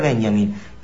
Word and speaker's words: Benjamin: Se escribe como Benjamín, pero sin acento, sin tilde en la I. Y Walter, pero se pronuncia Walter. Walter Benjamín, ¿sí? Walter Benjamin. Y bueno,